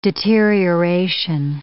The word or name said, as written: deterioration